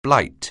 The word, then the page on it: blight: anglais